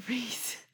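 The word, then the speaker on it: Rhys: And that guy Rise. – Reese. What the fuck? In what universe would his name be Rise? – It's spelled R-H. – Oh, yeah, it Y. is actually a weird spelling.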